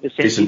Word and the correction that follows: Vicentini: the ruckman? – Vicentini, yeah. – Vicentini.